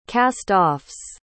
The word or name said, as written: cast-offs